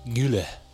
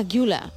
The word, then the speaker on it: Güler: Creo que Arda es Arda Güler. – Ah, Güler.